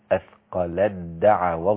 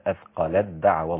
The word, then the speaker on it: أثقلت دعوا: هنا عندي التاء مع الدال إدغام، فلا تنطق التاء أبدا، هتنطق الدال مباشرة بعد اللام. أثقلت دعوا الله، نعم، فلما أثقلت دعوا الله، نعم.